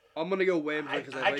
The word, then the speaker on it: Wamsley: Calgary acquires Rob Ramage and Rick Wamsley. Yes, I said it right. Wamsley. – It's not Ramsley. Wamsley.